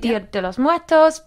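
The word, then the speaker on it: Día de los Muertos: Be it Halloween, be it Dia de los Muertos. Am I saying that right? – Yeah. – Be it Dia de los Muertos, be it Allerheiligen in Germany, be it Diwali.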